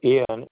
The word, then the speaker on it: Iain: I-A-I-N spelled. – The spelling of Ian is I-A-I-N. It's the Gaelic.